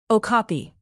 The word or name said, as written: okapi